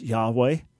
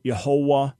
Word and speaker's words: Yahweh: Now, as to the pronunciation, the best take that we have is pronounced Yahweh, but we're not 100% sure. We can be quite sure it's not Yehovah, which comes into English as Jehovah, but people have related to him as Jehovah.